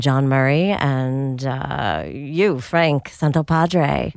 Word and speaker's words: Santopadre: No, I don't say the hard T. – Say it. – Santopadre. – Santopadre.